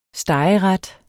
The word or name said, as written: stegeret